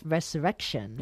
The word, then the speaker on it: Resurrection: sino que se llama Resurrección. ¿No lo he dicho bien? – Eso es Resurrection. – Resurrection.